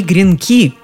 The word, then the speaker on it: гренки: Один гренок, два гренка, они гренки – то есть слово было мужского рода, да и ударение на последнем слоге, а вовсе не на первом, как мы привыкли.